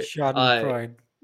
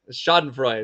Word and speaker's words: Schadenfreude: And it's really interesting because these are the kind of people that we exhibit Schadenfreude. If I'm pronouncing that right. – Schadenfreude. – Schadenfreude.